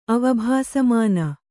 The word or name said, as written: ಅವಭಾಸಮಾನ